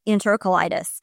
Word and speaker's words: enterocolitis: And she said, I recommend the ileostomy because then there are less complications and it prevents them from getting enterocolitis. Am I saying that right? – Oh, yeah. Yeah, that's the thing.